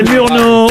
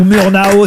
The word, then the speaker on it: Murnau: C'est Murnau ou Murnau, si vous voulez si vous voulez le prononcer effectivement en français ou à l'américaine, mais au départ c'est Friedrich Wilhelm Murnau, FW Murnau.